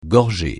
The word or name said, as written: gorgée